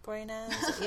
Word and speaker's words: Boreanaz: Like, I tried to watch Bones, which David Boreanaz, how do you say Boreanaz? – His name? Boreanaz? Yeah. That he's in. Like, boring as, basically.